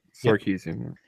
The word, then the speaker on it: Sarkisian: Sarkisian, did I say that right? – Sarkisian.